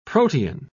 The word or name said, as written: protean